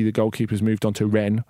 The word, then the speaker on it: Rennes: And we can say that Rennes are a good side. – How did you pronounce it? – Rennes. – Rennes.